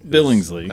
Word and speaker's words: Billingsley: B-A-U. – Bo, yeah. – Bo Billingsley? What did you say? – I'm assuming it's Billingsley. – Billingsley. – That's how it's pronounced.